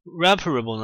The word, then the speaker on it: reparable: 哎，我刚才那个发音呢，其实是从它的动词发出来的。 repair，哎，这个 repair 它是修复的意思，哎，所以这个咱们这个 reparable 呢，它就是可修复的。